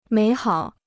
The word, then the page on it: 美好: měi hǎo